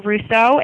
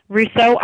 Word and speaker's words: Russo: So if you want to find me on Facebook, you could just Google my name, Rachel Russo. It's spelled R-A-C-H-E-L. Russo, R-U-S-S-O, and the letters M-S-M-F-T. So on Facebook, Rachel Russo, M-S-M-F-T.